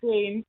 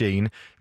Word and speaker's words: Jane: Det er med et helt almindeligt dansk navn, nemlig Jane, eller hvordan udtaler I det egentlig selv? Kære Jane. – Ja, er det Jane? – Jane. Ja. – Nå, okay, jeg prøver ellers lige at være sjov. Men øh, de stemmer, som du kan høre her, som sammen udgør, som med korrekt udtale, nemlig Jane.